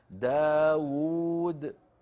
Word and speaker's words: داود: داود تكتب بواو واحدة تلفظ بواوين الواو الأولى مضمومة والثانية كذلك اليائين